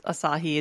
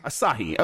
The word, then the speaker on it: Asahi: As- Asahi is usually how people say, As- like the beer. Asahi. Okay, thank you.